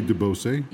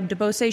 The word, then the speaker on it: DeBose: DeBose. – Yeah, DeBose.